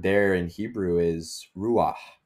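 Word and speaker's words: ruach (רוח): Good guttural H there, buddy. – I say, I think you got to get a little more of the Hebrew in there. It's ruach.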